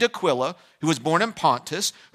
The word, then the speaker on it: Aquila: Husband's name's Aquila, wife's name's Priscilla. It rhymes.